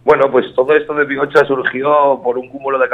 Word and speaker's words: Bihotza: Bioza. – Vale, Bioza.